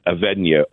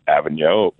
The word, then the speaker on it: Avegno: just calling for a martini, and then Avegno, or Avegno, or however we kind of mispronounce it. – A-V-E-G-N-O. Pronounce it however you like.